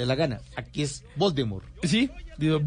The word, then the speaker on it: Voldemort: porque según ella no se pronuncia la T del final, sino que se pronuncia Voldemort, no Voldemort.